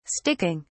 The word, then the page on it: sticking: British English